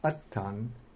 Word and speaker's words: atta: But in Pali, Atman is Attan, and the nominative singular is atta, with a long A.